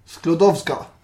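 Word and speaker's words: Skłodowska: Sklodowska. – Ja, Maria Sklodowska.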